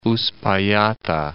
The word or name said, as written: Uspallata Pass